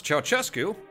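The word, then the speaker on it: Ceaușescu: Thank you to Sonia, who taught me how to pronounce Ceausescu,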